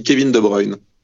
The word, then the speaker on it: De Bruyne: Est-ce que tu peux nous donner la vraie prononciation de KDB Parce qu'en Angleterre, ah, ils parlent de trucs. En France, on dit comment on dit vraiment On dit Kevin De Bruyne.